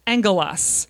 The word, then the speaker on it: angelos: It's pronounced angelos.